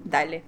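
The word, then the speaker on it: DALL-E: Нужен ли вообще промпт инжиниринг, как ты думаешь, если написание промптов станет простым с Дели? Дали, как он правильно пишется? Читается. – Дали, Дали, Дали, Дали.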